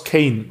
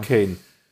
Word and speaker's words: Cayn: Ich buchstabiere das jetzt mal. C-A-Y-N. Sprich, wie spricht man es aus? Kane. – Kane, oder? Ja. – Kane.